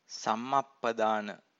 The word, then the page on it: sammappadana: Pali